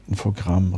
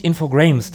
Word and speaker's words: Infogrames: Und ich weiß ehrlich gesagt gar nicht, ob Infogramm richtig ausgesprochen ist. – Also es heißt nicht Infogrames.